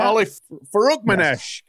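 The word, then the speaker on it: Farokhmanesh: Is that how you say it? – Ali Farukmanesh. Yes.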